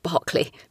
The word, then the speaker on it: Berkeley: Basically, the methodology, the prompts were written by The Guardian and fed to GPT-3 by a computer science undergrad at UC Berkeley, or Berkeley, I think you say in America. I think you call it Berkeley, but we would pronounce it Berkeley here in the UK.